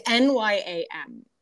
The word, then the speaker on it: Nyam: Jay's Nyam and Jam? It's NYAM. So Jay's Nyam and Jam.